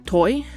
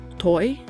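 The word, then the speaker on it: thôi: I double-checked online, and the way I just said it does seem like the most popular way to say it, so again, toi, and it means stop.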